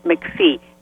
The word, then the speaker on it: McPhee: S-U-E, Fortin, F-O-R-T-I-N, and the last name, as you've pronounced it, McPhee, M-C-P-H-E-E.